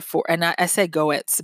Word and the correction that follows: Goertzel: but it's Goetzel. So it's Dr. Ben Goetzel, G-O-E-R-T-Z-E-L, Ben Goetzel.